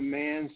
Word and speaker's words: Manns: Yeah, they can go to Mans Mackie, M-A-C-K-I-E, so that's Mans, M-A-N-N-S, no apostrophe, my last name is spelled with an S, Mans.